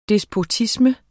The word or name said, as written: despotisme